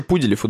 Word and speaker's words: Пуделев: Кеша Пуделев, ударение на «у».